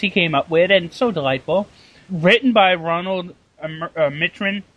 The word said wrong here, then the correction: Roland Emmerich: Roland. – Come on, dude, they're Americans. You should be able to pronounce their names. – I've never heard of their names. Roland Emmerich? – Emmerich, thank you. Roland Emmerich, Dean Devlin.